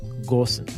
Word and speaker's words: Gosens: There's no umlaut. You do say the G. It's Gorsens.